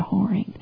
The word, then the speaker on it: whoring: I'll read that again because I pronounced one word wrong. I said warring instead of whoring.